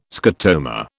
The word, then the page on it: scotoma: British English